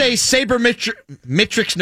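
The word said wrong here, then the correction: sabermetrics: nerd that I am. How do you pronounce that word? – Saber. Oh, sorry. Go ahead, Jerome. – Saber metric. – Okay, as a saber metrics nerd.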